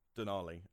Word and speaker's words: Denali: What brought on Denali? And am I pronouncing it right? – Yeah, yeah.